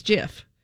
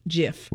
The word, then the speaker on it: GIF: Yeah, and he said, coming right from the mouth of Steve Wilhite, he said it is GIF. He said, they are wrong. The dictionaries are wrong. I invented it. It is a soft G pronounced like GIF.